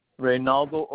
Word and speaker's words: Reinaldo: Reynaldo Ortiz. It's spelled R-E-I-N-A-L-D-O, Ortiz, O-R-T-I-Z.